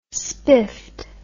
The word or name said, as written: spiffed